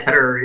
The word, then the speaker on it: Ketterer: Yeah, Ketterer.